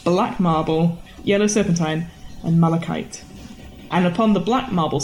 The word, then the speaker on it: malachite: Six steps from nearest to farthest are made of onyx, pink marble, lapis, black marble, yellow serpentine, and the colour I cannot pronounce, but it's malachite. Or malachite. – Malachite. – There you go.